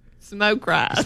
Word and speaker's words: Smoke Rise: Smokies. – No, smoke rise.